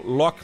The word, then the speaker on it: loch: A gente pronuncia assim, loch, que é lago, um, na, no dialeto escocês. Uh, tem, que, tem que realmente tem que forçar a garganta para falar.